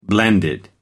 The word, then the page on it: blended: American English